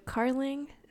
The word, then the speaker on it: Carling: Yeah, mine is Inclusive with Carling. So spelled like darling, but you replace the D with a C.